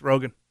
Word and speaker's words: Rogan: Is that how you pronounce that? – It's Rogan.